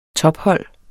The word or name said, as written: tophold